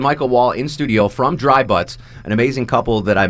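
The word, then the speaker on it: Dri Butts: Dry Butts, is spell it for it, because it's not... It's the traditional spelling. – Yeah, it's D-R-I-B-U-T-T-S.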